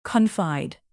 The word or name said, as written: confide